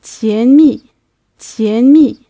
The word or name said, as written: tanmi